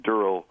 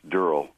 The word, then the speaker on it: Durrell: It isn't Durrell. I mean, it isn't Durrell. It's Durrell. I heard him correct the pronunciation to Dick Cavett years and years ago.